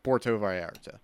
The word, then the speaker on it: Puerto Vallarta: How do you say Puerto Vallarta? – Try it. – Puerto Vallarta. – Yeah, you got that one.